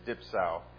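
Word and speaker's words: dipsaō: This is dipsau. Greek is how you pronounce it.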